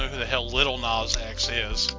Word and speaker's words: Lil Nas X: For some reason, I don't want to pronounce the S in that. I just want to call him Lil Nah.